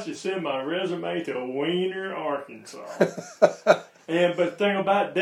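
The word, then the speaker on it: Weiner: It should have been pronounced Wiener, but I guess over time they called it Wiener.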